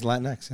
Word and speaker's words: Latinx: But is that how you pronounce it, though, Pimp? Can you hit that? It is Latinx.